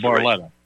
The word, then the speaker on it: Barletta: Luke Bartella, or if I pronounce Lou Barletta. – Barletta. Barletta, correct. Yeah.